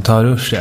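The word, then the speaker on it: taharrush: Jag är lite osäker på det uttalas, men Taharush är en... – Jag tycker det lät korrekt.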